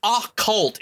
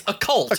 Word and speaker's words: occult: Occult? Who pronounces occult? It's occult. – Occult, yeah. – Just say it's the occult. Like, occult.